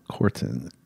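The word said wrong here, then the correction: Kwartin: I know I'm not pronouncing it right. It's Kvartin, but it's K-W, Kvartin, Kvartin.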